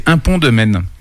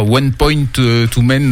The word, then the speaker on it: Un Pont de Maine: le nom c'est Un Pont de Maine. – Ok. – Voilà, et non pas One Point to Maine, non, comme on l'avait déjà dit.